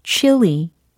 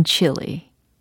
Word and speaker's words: Chile: 그러면 어 칠레라고 하면 되지 않을까 생각하시겠지만 그게 아닙니다. 잘 들어보세요. chili, chili, chili. 아, 칠레가 아니라 chili. 라고 발음이 되는군요. 그죠? 물론, 어, chili sauce라고 할 때 그 chili하고 동일한 발음입니다.